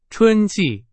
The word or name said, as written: 春季